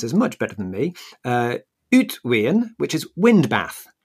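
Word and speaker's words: uitwaaien: Yeah, so that would be an Uitweeën.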